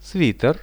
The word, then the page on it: sviter: Russian